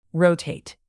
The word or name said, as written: rotate